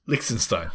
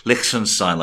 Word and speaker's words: Liechtenstein: Ah, you said Lich, Liechtenstein like a scouser or something. That's wrong. It's Lich, Lichten, Liechtenstein. I hope my German friends can confirm that I'm saying it correctly.